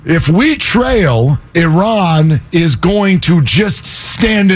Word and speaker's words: Iran: Pronounce it right. – Iran 1.